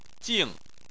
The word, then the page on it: jing4: chinese